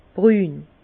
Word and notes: brün: Bas-Rhin; Herrlisheim

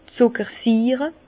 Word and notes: d' Zuckersiire: Bas Rhin; Herrlisheim

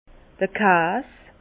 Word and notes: d'r Kas: Alsatian; Bas-Rhin; Herrlisheim

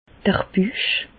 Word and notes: d'r Büch: Bas-Rhin; Reichshoffen

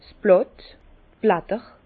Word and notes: 's Blàtt: Bas Rhin; Herrlisheim

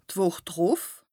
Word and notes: d' Wùch drùf: Bas-Rhin; Strasbourg

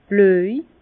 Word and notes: blöj: Bas-Rhin; Herrlisheim

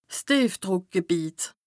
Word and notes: 's Tìefdrùckgebìet: Bas-Rhin; Strasbourg

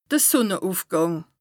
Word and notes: de Sùnneùfgàng: Bas-Rhin; Strasbourg

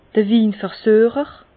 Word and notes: de Winversuecher: Bas Rhin; Herrlisheim

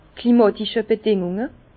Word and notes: klimatische Bedingùnge: Bas-Rhin; Herrlisheim

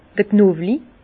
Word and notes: d'r Knowli: Alsatian; Bas-Rhin; Herrlisheim

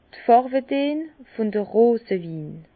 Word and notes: d' Fàrwedeen vun de Rosewin: Bas-Rhin; Herrlisheim

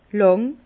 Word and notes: làng: Bas-Rhin; Herrlisheim

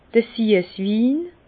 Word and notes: d'r Süesswin: Bas-Rhin; Herrlisheim

